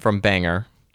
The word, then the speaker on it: Bangor: It wasn't far from Bangor, which I believe is how the locals would pronounce it.